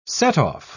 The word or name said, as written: setoff